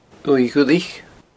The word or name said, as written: uaimh dhuilich